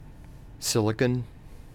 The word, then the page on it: silicon: American English